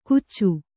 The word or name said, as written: ಗುಚ್ಚು